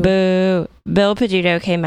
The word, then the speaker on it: Peduto: Okay, I hope I'm not butchering the name, but Bill Peduto? Peduto? – Sounds right to me.